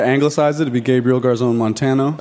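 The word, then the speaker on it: Garzón Montano: Multi-instrumentalist, producer, songwriter, Gabriel Garzon Montano. Am I saying that right? – Yeah, I mean, you could say Gabriel Garzon Montano.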